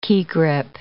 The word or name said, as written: key grip